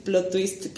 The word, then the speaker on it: plot twist: A ver, siento ahí les va el plot twist, que no sé si se dice así, porque no hablo bien inglés, pero este.